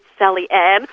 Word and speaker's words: Sally Ann: But I'll tell you, being Sally Ann, there's rarely a day that goes past that I don't have to say, no, it's not Sally, it's Sally Ann. – Yeah. – Or I have to spell it, or, you know, no, not Diane, it is Sally Ann.